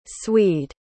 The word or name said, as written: swede